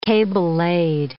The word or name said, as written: cable-laid